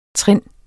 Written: trind

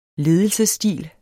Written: ledelsesstil

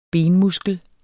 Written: benmuskel

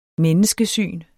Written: menneskesyn

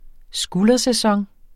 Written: skuldersæson

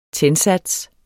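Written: TÆNDSATS

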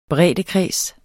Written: breddekreds

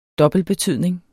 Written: dobbeltbetydning

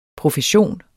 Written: profession

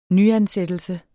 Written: nyansættelse

